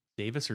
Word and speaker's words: Davies: Davis or Davies? – Well, it's spelled Davies, it's pronounced Davis. British, right.